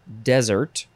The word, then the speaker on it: desert: It's pronounced either desert or dessert, depending on which form we're talking about. We have four of them, but only three of them are in this episode, and you gotta wait until the next episode to hear the fourth form. First form, this one is pronounced desert, D-E-S-E-R-T, noun from the 13th century.